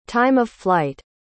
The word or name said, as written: time-of-flight